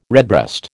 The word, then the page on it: redbreast: American English